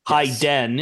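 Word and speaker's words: Heiden: Hyden is how you say it, which is appropriate.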